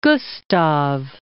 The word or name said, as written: Gustav